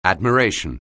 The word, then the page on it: admiration: anglais